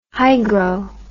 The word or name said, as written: hygro